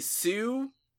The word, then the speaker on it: Hsu: Andre 3000, whole lot of Nina Moffat, Rob Moose, I'm not sure I want to meet this Moose, Stephanie Sue, I assume that's how you pronounce that name, HSU, Moses Sumney, David Byrne, Randy Newman.